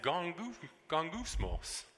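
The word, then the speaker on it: gongousmos (γογγυσμός): You pronounce it gongousmos. Gongousmos.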